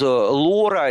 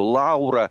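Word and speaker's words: Laura: А вот Лора или, как многие называют ее, Лаура, отмечу сразу, что в английском языке и во многих других языках мира и ее произношение ее имени допустимо допустимо и как Лора, и как Лаура.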